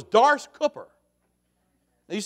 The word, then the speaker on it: Doris: C-O-O-P-E-R. Now, the fact is, that's not how we say it where we come from. It's Dars. Dars. That's how you say it. It's D-O-R-I-S, but Dars.